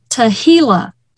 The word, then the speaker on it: tehillah: And I want to really dig into this word, and it's called tehillah. That's a little bit harder to spell. This one is spelled T-E-H-I-L-L-A-H.